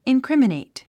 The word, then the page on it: incriminate: inkrímənèit; インクリミネィト